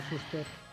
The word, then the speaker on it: Fuster: Fuster. – Y sí, en mi pueblo había uno que era Fuster, por eso dudo. – Pero aquí como Fuster es carpintero, pues lo hacemos con el acento.